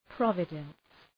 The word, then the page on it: providence: ‘prɒvıdəns